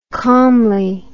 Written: calmly

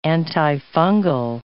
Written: antifungal

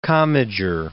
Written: Commager

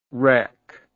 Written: rec

